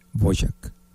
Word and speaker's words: Dvořák: Y lo pronuncian de diferentes maneras. Hay, algunos dicen Antonín Dvořák, otros dicen Dvořák. En realidad, la pronunciación en el idioma checoslovaco, que es el idioma original, es Dvořák.